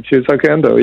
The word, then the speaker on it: Shusaku Endo: the influence for a lot of it is Shusaku Endo. Is that how you say his name? – That's you said it right. That's amazing. Shusaku Endo, yes.